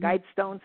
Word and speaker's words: Georgia Guidestones: Are we saying that correctly? – Yes, Georgia, like G-E-O-R-G-I-A, like the state of Georgia in America, uh-huh. The Georgia Guidestones, G-U-I-D-E, like guide, and then stones.